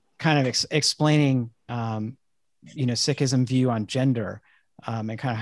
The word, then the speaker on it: Sikhism: He's a Sikh, S-I-K-H, and I really appreciate having conversations with Jay about it because I haven't really known anyone who practices Sikhism. Did I pronounce that right? – Mm-hmm. You could even say Sikhi, which would be the spirit of being a Sikh.